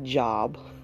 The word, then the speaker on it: Job: Now, for my listeners who are not familiar with the Bible, it's Job, J-O-B, it's spelled like job. But it's not Job. His name is Job, and there's a whole book titled after him.